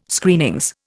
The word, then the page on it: Screenings: Indian